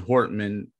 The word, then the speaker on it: Hortman: Okay, well, I just found out there's a Ron Hortman Pandora station, so I'm kind of excited about that.